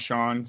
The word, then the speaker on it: Jon: Dude, my whole life, everyone called me John in school because my name's spelled J-O-N, but it's pronounced Sean.